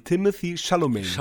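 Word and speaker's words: Timothée Chalamet: ich kann ihn nicht aussprechen, Shambhalabam Shaladambalan und Timothy Chalamet.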